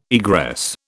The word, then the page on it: egress: British English